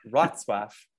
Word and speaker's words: Wrocław: Wrocław is the, I think, correct way to pronounce it.